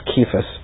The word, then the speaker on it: Kephas: Then 3 years later, I went up to Jerusalem to become acquainted with a lot of people say Cephas, but it's actually pronounced Kephas, and stayed with him 15 days.